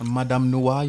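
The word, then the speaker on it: Madame Noire: There was some fucking random ass article, and niggas tore that shit apart yesterday on, I don't even, Madame Noir. Was that how, my French translator, Madame Noir? Is that how you say it? – Madame Noir. Yes, it is.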